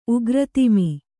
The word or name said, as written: ಉಗ್ರತಿಮಿ